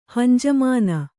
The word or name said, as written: ಹಂಜಮಾನ